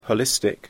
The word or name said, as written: holistic